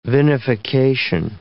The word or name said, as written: vinification